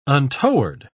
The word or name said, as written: untoward